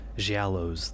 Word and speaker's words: Giallo: Giallo's the correct Italian pronunciation. – Giallo's soft G.